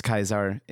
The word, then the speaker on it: Caesar: Because Kaisar is the proper pronunciation for Caesar. – Yeah. Well, yeah. In German. – Yeah. Also in Latin. It's Latin origin.